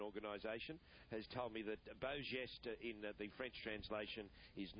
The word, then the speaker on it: beau geste: He said the correct way to say beau geste is beau geste.